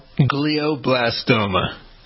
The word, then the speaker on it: glioblastoma: Glioblastoma. – All right. So what's the story? – Glioblastoma, Doug, this is an aggressive type of cancer that can occur in the brain or spinal cord. Glioblastoma, it forms in cells that are called astrocytes that support nerve cells.